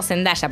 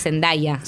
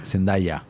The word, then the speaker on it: Zendaya: Yo lo digo medio argento Zendaya, pero me parece que es Zendaya. – Zendaya.